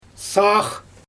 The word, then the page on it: saax: Xyzyl